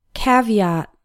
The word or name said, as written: caveat